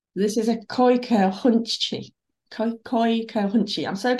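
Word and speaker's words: Kooikerhondje: The Nether Denali's Koika Hunchi Club of the USA breaks down the Koika Hunchi pronunciation. So you say effortlessly, Koika Hunchi.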